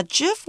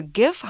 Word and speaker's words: GIF: 没错，sticker 就是我们在 Line 上面会看到的通讯软体上面的贴图。那 GIF 或 GIF 啊，两个发音都是可以的。它呢比较专业的说法是图像互换格式，那就是一种档案格式，常常被用来制作网络上的动图。